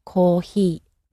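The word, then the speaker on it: kohee (コーヒー): So the Japanese sound is somewhere in between an H and an F. So the word for coffee is kohee.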